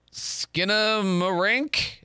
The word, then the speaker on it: Skinamarink: All right, I uh, I know I'm going to mispronounce this, but Skinamarink? – Am I close? No, that's that's that's at least to the best of my knowledge, that's correct.